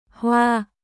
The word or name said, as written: Hoa